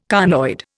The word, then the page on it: conoid: Indian